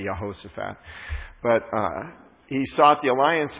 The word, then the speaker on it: Jehoshaphat: And so he sought the alliance of King I want to get it right, because last week I said it wrong. Jehoshaphat. I said Jeroboam a couple of times, not meaning to, and it's, you know, they're all J's, they're all the same.